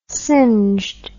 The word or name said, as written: singed